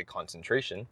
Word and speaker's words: concentration: So, for example, concentration, if you don't actually know how to pronounce that word, you might think con-ken-tra-tien. Con-ken-tra-tien. And if you have generations or different levels of people sight reading with no feedback from somebody who is from North America, Britain, or Australia, giving you that feedback, oh, it's actually concentration, then you might not catch that mispronunciation.